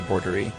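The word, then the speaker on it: boerderij: And now the word for farm is pronounced, he gave me an English pronunciation, thank goodness, bordery.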